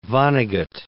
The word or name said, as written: Vonnegut